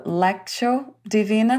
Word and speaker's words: Lectio Divina: the Lectio Divina. I'm not sure if you pronounce it that way. – Yep.